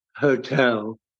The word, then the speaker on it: hotel: I was also trying to remember whether hotel is a feminine rhyme or because the emphasis was on the second syllable of the word, unlike, you know, rubbish, where the emphasis is on the first syllable.